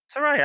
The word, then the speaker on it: Saraya: Saraya, I keep saying her name wrong. I'm just going to start calling her Slayer. – Saraya, I think you're saying it right.